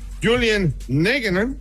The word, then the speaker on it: Nagelsmann: Habló también el director técnico de selección nacional más joven de toda la justa deportiva, Julian Nagelsmann. Ojalá que no haya devastado su... ¿Así se pronuncia, pueblo hermano? – Está perfecto, sí. Amigo, está perfecto.